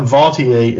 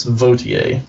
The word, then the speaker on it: Vautier: But Vautier would, and that is actually the proper pronunciation. It's not Vautier, it's Vautier. I learned that.